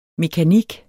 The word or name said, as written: mekanik